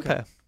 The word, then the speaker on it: Lampaert: Eric Lamper. – Okay. – And spell that, because people, look, I would... – L-A-M-P-A-E-R-T. – Whoa!